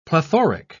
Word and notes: plethoric: British English

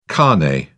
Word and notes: carnet: British English

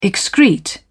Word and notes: excrete: British English

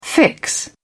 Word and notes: fix: British English

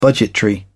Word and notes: budgetary: British English

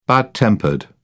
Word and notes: bad tempered: British English

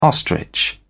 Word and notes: ostrich: British English

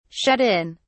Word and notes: shut in: British English

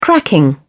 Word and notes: cracking: British English

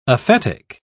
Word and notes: aphetic: British English

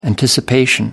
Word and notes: anticipation: American English

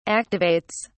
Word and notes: activates: British English

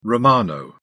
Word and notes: romano: British English

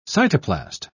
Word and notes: cytoplast: British English

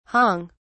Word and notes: hong: British English

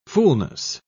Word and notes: fulness: British English

